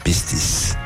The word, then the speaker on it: Pistis: Uh, de fapt, mie îmi place cu, uh, cum se pronunță pe grecește, știi? Că e Pistis. E ca sifilis.